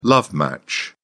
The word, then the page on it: love match: British English